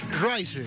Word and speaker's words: Rice: Rice, sería Rice, si se pronuncia Rice, Rice. ¿Cómo sería? Rice.